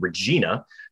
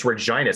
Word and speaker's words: Regina: in Regina, Saskatchewan. Excuse me, it's Regina, Saskatchewan. Yes. – Say, please don't butcher that.